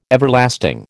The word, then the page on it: Everlasting: American English